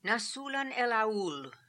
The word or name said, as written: Nasoolan el Aool